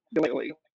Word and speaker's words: Uiagalelei: but I'm going to look at – I'm going to have to see how DJ Uiagalelei, that's how you say it, Uiagalelei, does in the first quarter.